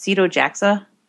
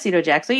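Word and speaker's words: Sito Jaxa: Is that pronounced Cito Jaxa? – Yeah, that's what I'd say. – Yes. – That's what I would say, too. And if you said Cito Jaxo, you'd be right.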